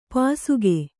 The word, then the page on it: ಪಾಸಿಕೆ: Kannada